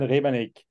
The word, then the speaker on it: Rebernik: I'm Christian Rebernik.